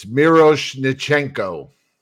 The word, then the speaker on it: Miroshnichenko: It's Miroshnichenko. – What? – Miroshnichenko. That's how you say his name.